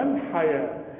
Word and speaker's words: الحياة: واحد الاولاني يعزل نقول وما الحياه، انما لو وقفنا نقول وما الحياه. كلمه الحياه والزكاه والربا دايما بتكتب بالواو، لكن نكتبها بالواو وتنطق بالالف.